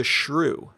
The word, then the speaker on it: SRU: Next up, another podcast, Taming the Shrew. Now, you not might not know how to spell this. It's Taming the S-R-U, but it's pronounced shrew.